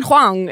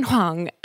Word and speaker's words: Huang: And we loved Suzanne Huang is what we said, because that's how she pronounced her name. She's like, I'm Suzanne Huang.